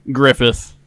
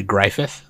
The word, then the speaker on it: Griffith: I gotta go with my boy, the bussy of Midland, Griffith. – You're saying it wrong. It's actually Griffith.